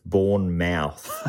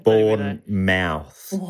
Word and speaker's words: Bournemouth: I think English people will be annoyed by that pronunciation. It's Bournemouth. we'll get tweets if we don't correct it. – Yeah, thank you for Bournemouth.